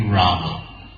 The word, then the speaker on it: Rama: In Sanskrit, I'm not an expert by any means, but I've learned that every consonant has a sort of a short A after it. So it would be Rama.